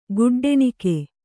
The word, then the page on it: ಖಡ್ಡಣಿಗೆ: kannada